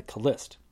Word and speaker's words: Khlyst: In his late teens, he reportedly fell in with a cult known as the Khlyst, which is spelled K H L Y S T.